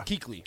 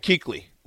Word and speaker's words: Kuechly: Luke, how do you pronounce Luke's last name? Is it Kuechel or Kuechel? From Boston College. – Is that the linebacker? – Yeah. – Kuechly. – Kuechly. Luke Kuechly. That's a definitely silent U-E-K. It's spelled K-U-E-C-H-L-Y. L-Y. Yep. Kuechly.